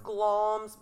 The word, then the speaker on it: Glamis: It's pronounced Glamis, but it's spelled G-L-A-M-I-S, because after I heard this story, I wanted to look it up and find out so much about it.